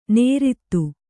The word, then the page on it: ನೇರಿತು: Kannada